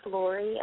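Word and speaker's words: Lori: My name is Laurie, and I'm from Oregon.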